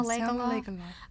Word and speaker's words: Lagerlöf: I think Lagerlöf. – Selma Lagerlöf, a Nobel Prize winner who died quite a few years ago, a Swedish woman.